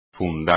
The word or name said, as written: fundar